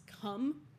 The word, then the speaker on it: Cwm: From here, the climbers will make their way to the Western CWM, which is actually pronounced Cum, which is Welsh for valley, but my inappropriate little 14-year-old brain is like, Cum.